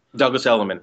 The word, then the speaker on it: Douglas Elliman: He's currently an agent with Douglas Elliman. Did I pronounce that right? – Douglas Elliman.